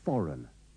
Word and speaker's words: foreign: In these sentences, don't pronounce the G in foreign.